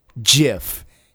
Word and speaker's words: GIF: It is a soft G pronounced GIF.